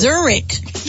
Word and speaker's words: Zurich: And at number one, Zurich. Be the producer. How do I say that? – This is why we're not on the smartest list. – Exactly. – It's Zurich.